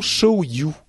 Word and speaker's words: Show You: Alors vous, vous avez peut-être vu en fait quand j'en avais parlé la première fois, euh, c'est l'application Show You, comme ça se prononce, S-H-O-W-Y-O-U,